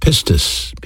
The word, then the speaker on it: pistis: You know, faith is a noun, and in the Greek language it's pronounced pistis, P-I-S-T-I-S. It has a verb counterpart, and that's the word believe, pistuo, P-I-S-T-E-U-O.